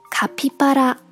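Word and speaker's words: カピバラ: 叫做卡皮巴拉，卡皮巴拉，在这个词里面，第二个假名 p，它还是读作 p，尽管它没有出现在单词的开头，我们还是可以轻易的发出标准的本来的送气音。而第三个假名 pa，在这里面读作 ba，在上面这组单词里面。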